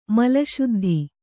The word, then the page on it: ಮಲಶುದ್ಧಿ: Kannada